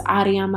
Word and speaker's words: Ariam: My name is Ariam Alula.